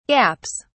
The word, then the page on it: gaps: British English